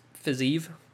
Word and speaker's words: Fiziev: Of course, this is pronounced Fiziev. Fiziev, of course, but you know, I'm not a UFC announcer.